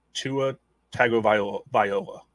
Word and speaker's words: Tua Tagovailoa: So another one here that's kind of interesting is Tua Tagovailoa.